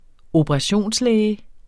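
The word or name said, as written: operationslæge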